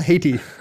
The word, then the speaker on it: Haiti: Hiati. – it's pronounced Haiti.